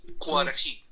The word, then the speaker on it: Cuarají: Ese disco, ¿cómo se pronuncia? ¿Curají? ¿Cura? ¿Cómo es? – Cuarají, cuarají. – ¿Cuarají? ¿Cuarají? ¿Cuarají? – Eso.